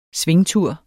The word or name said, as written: SVINGTURE